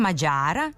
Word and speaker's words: Mađar: Peter Mađar je Mađar. Od svih Mađara za premijera izabrali Mađara. – A prema saopštenju Odbora za standardizaciju srpskog jezika, pravilno je jedino ovako.